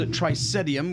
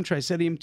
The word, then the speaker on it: Trisaetum: It's a Willamette Valley Riesling called Tricetium, which is spelled T-R-I-S-A-E-T-U-M, Tricetium 2013 from J&A Frey Vineyards, F-R-E-Y.